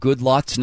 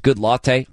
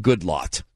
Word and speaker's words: Goodlatte: It's an event, and I mispronounced Bob Goodlatte's name as Goodlatte. I don't know how to pronounce this either. Aussie Fest, and by the way, his name should be Goodlatte, okay? It's a mean trick because his name says latte in it. And I'm filing a complaint. That's it. Harshly worded, I might add, to Bob Goodlatte. Change your name. Goodlatte. That's how it's pronounced.